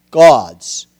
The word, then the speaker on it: goads: In Ecclesiastes 12:11, the words of the wise our gods, G-O-A-D-S, gods. It's pronounced G-O-W-D-Z, gods, okay.